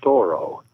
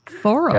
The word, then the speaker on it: Thoreau: Actually, I will tell you that the family pronounces it Thoreau. – Thoreau. – And in sort of the French way.